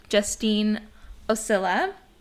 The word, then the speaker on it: Justine Osilla: Today we are sitting down virtually with Justine Osilla, and did I pronounce that right? – Yes, you did.